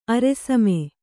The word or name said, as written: ಅರೆಸಮೆ